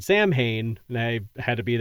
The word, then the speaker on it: Samhain: Some people say Samhain, which is how it's spelled. But from everything I've seen or read, it's Samhain or some sort of variation of that.